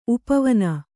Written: ಉಪವನ

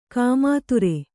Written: ಗೋಮೂತ್ರ

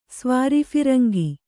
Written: ಸ್ವಾರಿಫಿರಂಗಿ

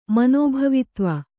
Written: ಮನೋಭವಿತ್ವ

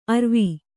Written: ಅರುವೆ

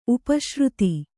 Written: ಉಪಾಶ್ರಿತ

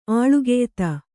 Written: ಆಳ್ಗೆಯ್ತ

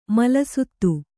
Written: ಮಲಸುತ್ತು

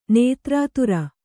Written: ನೇತ್ರಾತುರ

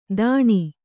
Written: ಧಣಿ

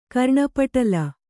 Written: ಕರ್ಣಪಟಲ

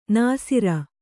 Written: ನಾಸೀರ

